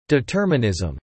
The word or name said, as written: determinism